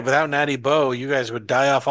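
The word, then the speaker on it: Natty Boh: Oh no, not natural light, Natty Bow. Not Natty Light, Natty Bow. Natural National Bohemian. That's our Maryland beer. – Natty Bow? – Natty Bow.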